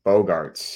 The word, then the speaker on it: Bogaerts: Bogaerts.